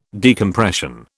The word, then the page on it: decompression: British English